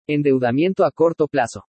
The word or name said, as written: ENDEUDAMIENTO A CORTO PLAZO